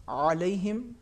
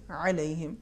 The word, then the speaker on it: علیہم: جیسے علیہم جی. صحیح تلفظ نہیں ہے صحیح ہے علیہم آواز میں حلق میں تنگی ہوگی لیکن آواز کو ہم تفخیم کے ساتھ نہیں ادا